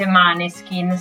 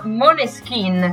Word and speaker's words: Måneskin: Sì, sì, stanno facendo faville, però innanzitutto sfatiamo un mito, perché non si dice Maneskin, sai Nick? Si dice in realtà Måneskin, che in danese vuol dire chiaro di luna.